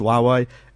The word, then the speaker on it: Huawei: We're going to chat about everything to do with Huawei.